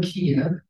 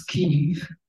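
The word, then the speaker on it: Kyiv: We used to call it Kiev, you know, now that's the Russian pronunciation. Now it's Kiev, which is the Ukrainian pronunciation.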